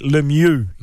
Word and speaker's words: Lemieux: Le R, il l'avait moins, là, mais le mieux.